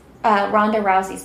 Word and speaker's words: Ronda Rousey: It is Ronda Rousey's book.